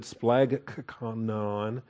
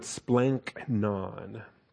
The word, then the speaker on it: splanchnon: So when the gamma comes in front of a chi, instead of trying to pronounce it splagchnon, it's just awkward. You need to blend that, and the Greeks would blend that.